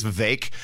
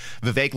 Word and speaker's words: Vivek: By the way, I think we've been pronouncing it wrong. I think it's Vivek. Vivek like cake is how I remember that.